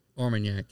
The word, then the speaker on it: Armagnac: Armagnac, yeah. – Armagnac. – Daylord. – Yeah, bottle, 25 years old. – Yes. It's only about $80. So, yeah, I turned Seth on to the 25-year-old Armagnac Daylord.